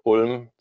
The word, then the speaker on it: Ulm: My first job out of college was in southern Germany, in a town called Ulm.